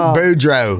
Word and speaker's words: Boudreaux: Boudreaux.